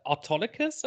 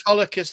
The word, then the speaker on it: Autolycus: And then you get Autolycus, who says, I can't pronounce his name. How do you pronounce his name? Rufus Sewell's character? – Autolycus, I think. – Autolycus, that's it.